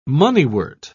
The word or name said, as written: moneywort